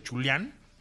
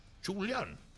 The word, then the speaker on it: Jolion: ¿Sabes qué significa Yolión? Y en, chino se dice Chulián. Chulián. Yo no tengo muy buena pronunciación china, tampoco este, eh, en español, pero se escribe J-O-L-I-O-N.